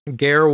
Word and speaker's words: Ger: Unless I'm pronouncing his first name wrong, which might be Ger. It's G-E-R. Maybe okay. It's Ger. But his last name is Wolf.